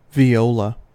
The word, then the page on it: viola: American English